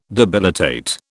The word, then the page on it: debilitate: British English